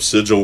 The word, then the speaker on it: sigil: I mean, people could look up sigil work, and they will see what I'm talking about. – How do you spell that? – It's S-I-G-I-L. So sigil.